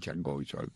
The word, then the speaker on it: Chargois: Tenemos que el ganador fue Chargois. Se escribe aquí Chargois, pero como dicen los americanos, le dirán Chargois.